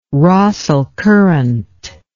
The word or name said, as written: Rossel Current